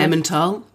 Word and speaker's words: Emmental: Em, Emma, Emmental, Emma, Emma, Emmental.